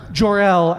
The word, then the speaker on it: Jor-El: Jor-El, as in the father of Superman.